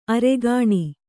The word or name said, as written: ಅರಗಿಣಿ